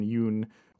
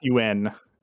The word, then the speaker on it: Yeun: So it's not UN as some people might think, as I probably thought before I heard that.